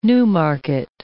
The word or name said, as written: newmarket